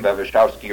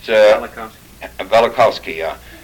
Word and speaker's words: Velikovsky: And it's interesting, this, I don't know how to pronounce his name, Bevishovsky or whatever it is, on his Collision of Worlds and so on. This last week's... – Velikovsky.